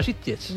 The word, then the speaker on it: Sitges: No ha dicho Sitges, ha dicho Sitges. – Lo ha dicho muy bien. – Lo ha dicho con buena pronunciación catalana.